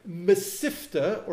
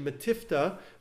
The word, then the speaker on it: mesifta: It is also called, there's an Aramaic word for it, mesifta, or in um, Sephardic pronunciation, metifta, which is a uh, just another Aramaic word, which also means a place for sitting, like yeshiva.